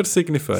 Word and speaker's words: Signify: Signify.